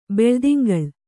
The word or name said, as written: ಬೆಳುದಿಂಗಳ್